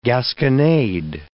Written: gasconade

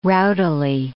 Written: rowdily

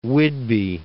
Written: Whidbey Island

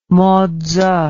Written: motse

